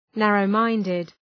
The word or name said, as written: narrow-minded